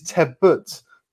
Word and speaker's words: Tebbutt: Until for the third week in a row, you have pronounced my last name wrong. It is Tebbutt. Not what you say. I don't know what you say, but it's wrong.